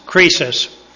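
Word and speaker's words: krisis (κρίσις): The Greek word is pronounced krisis, spelled K-R-I-S-I-S, as in crisis in the English language, except the C is K.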